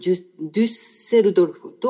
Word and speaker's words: デュッセルドルフ: それではトークを続けていきたいと思うんですが、先週、先々週、映像作家でアーティストの武田新平さんのトークをお届けしました。ドイツのデュッセルドルフ。どう発音するんですか？デュッセルドルフ。